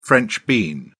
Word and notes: french bean: British English

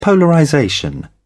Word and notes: polarization: British English

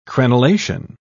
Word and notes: crenelation: British English

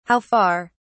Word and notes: how far: British English

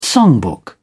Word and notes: songbook: British English

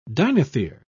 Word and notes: dinothere: British English

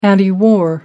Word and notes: antiwar: British English

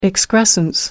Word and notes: excrescence: American English

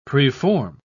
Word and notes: preform: British English

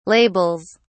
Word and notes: labels: British English

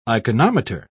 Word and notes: iconometer: British English

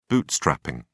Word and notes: bootstrapping: British English

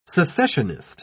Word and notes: secessionist: British English

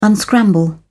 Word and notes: unscramble: British English

